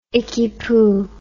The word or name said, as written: ickypoo